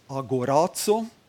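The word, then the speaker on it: Agorazo (ἀγοράζω): Das Agorazo, dann Ex Agorazo und Lutro spricht man es aus.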